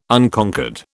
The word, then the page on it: Unconquered: British English